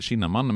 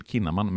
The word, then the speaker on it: Kinnaman: Man tror kanske att det uttalas Joel Kinnaman, men egentligen uttalas det Joel Kinnaman med hårt K.